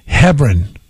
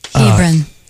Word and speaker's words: Hebron: Aaron from Hebron. – Hebron. – I say that wrong every time.